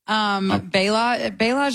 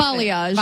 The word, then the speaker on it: balayage: What is the difference between balayage and ombre hair coloring? What am I supposed to? – Balayage, is that how you say it? How do you balayage. – Say it? Balayage, that's it.